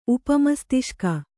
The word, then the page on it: ಉಪಮಸ್ತಿಷ್ಕ: upa mastiṣka